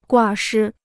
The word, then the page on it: 挂失: guà shī